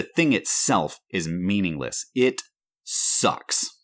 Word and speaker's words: SUX: How would you pronounce SUX? – It, it, it sucks. – Sucks. Yeah, six thousand sucks,